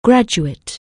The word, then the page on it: graduate: anglais